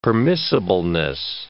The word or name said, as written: permissibleness